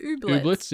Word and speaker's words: Ooblets: Ooblets. Oh, I think that's how you say it. Ooblets, yeah.